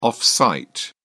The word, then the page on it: off-site: British English